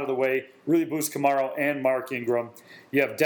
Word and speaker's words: Kamara: Is it Kamara? – Kamara, yeah.